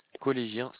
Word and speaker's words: Collégien: Collégien CEDEX, comme ça se prononce, collégien comme un collégien, CEDEX, CETEX.